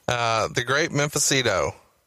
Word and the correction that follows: Mephisto: The great Mephisto, Frankie Lane, man. He was a legend in your, he's from your part of the woods in Alabama. – Yeah, I just mispronounced the fuck out of it. My bad. – That's okay. It's great Mephisto.